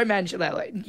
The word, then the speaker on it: Manganiello: Just sexually objectifying Joe Milagriano. – Oh, is that how you say it? – That's Milagriano. – He is hot. Google him. Joe Manganiello.